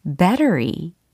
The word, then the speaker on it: battery: 자, 배터리라고 이야기를 하면 안 된다라는 것이 포인트가 되겠습니다. 오늘 연습할 단어는 battery 이겁니다.